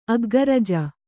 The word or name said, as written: ಅಗ್ಗರಜ